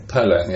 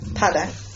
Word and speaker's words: Padang: Padang, it's pronounced. – Padang. – Padang, yes. – So the emphasis is on the... – On pad. – Padang.